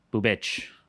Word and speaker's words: Bubich: Last name, Boob? – Bubich. – Bubich. – That could be mispronounced, but it is like B-U-B-I-C.